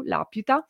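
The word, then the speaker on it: Laputa: Uh, poi vi ho citato l'Aputa Castello nel Cielo e uh, in particolare questo, uh, questa, questa, parola proprio l'Aputa, che in giapponese viene detta l'Apita, la più, perché eh, c'è mm, questa sillaba che, che, legge così la U, l'apiuta, eh,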